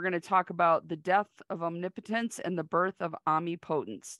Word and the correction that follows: amipotence: Did I say it right? – I say it amipotence, but, you know, whatever.